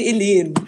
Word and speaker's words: Helene: Nee, Helene. Also mit Betonung auf dem zweiten E und alles. Ganz, ganz viel E, wie das Alphabet E.